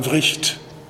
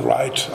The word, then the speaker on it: Wright: Van Vricht, im Englischen wird er meistens von Wright ausgesprochen, ist aber ein finnischer Schwede. Also insofern wäre Vricht der richtige, die richtige Art der Aussprache.